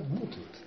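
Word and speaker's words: mutant: Мутант. На последнем слоге не бывает ударения, да? Мутант, так? – Почести меняют навыки.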